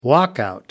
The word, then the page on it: walkout: American English